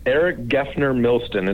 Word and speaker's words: Eric Geffner Milston: Did I say your name correctly? Say it again, please. – Yes, Eric Geffner Milston. It's a long one.